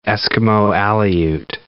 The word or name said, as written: Eskimo-Aleut